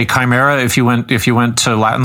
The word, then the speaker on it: chimera: She uses the word chimers, which, as it turns out, is a mispronunciation of the ancient mythic term chimera.